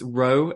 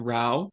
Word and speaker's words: Rowe: Just a little side note, it's Rowe and not Rao. But no problem whatsoever. At least you pronounce my name properly, because the French, they don't know how to pronounce my name.